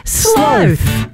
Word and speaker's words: sloth: And the world collectively went, oh, so that's how you say it, because he went sloth.